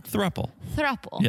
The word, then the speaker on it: thruple: Thruple, whatever you pronounce it. – Thruple. Yeah. – Yeah. – You have thruple trouble. – I do. Yeah. It sounds like truffle and it makes me hungry.